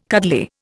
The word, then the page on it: cuddly: Indian